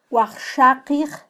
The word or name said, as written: wajxaqij